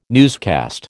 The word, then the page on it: newscast: American English